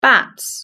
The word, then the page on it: bats: British English